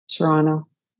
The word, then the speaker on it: Toronto: And that's not usually how I say Toronto, but that's how a lot of people say Toronto. So Toronto, Ontario, that's how we say it in Ontario. You know, I think most of us don't. Isn't that how you say it? – Toronto. – Yeah. We say Toronto, yeah, not Toronto. Toronto. But, you know, you might not know what I'm talking about if I don't say Toronto.